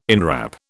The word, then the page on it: inwrap: British English